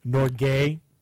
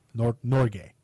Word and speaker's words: Norge: Norgay? – Really? – Yes. In the Norwegian language, that's how you pronounce Norway. Norgay.